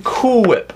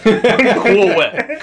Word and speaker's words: Cool Whip: I want some Cool Whip. – Oh, yeah, we did that too. – Cool Whip. Cool Whip. Why are you putting so much emphasis on the H?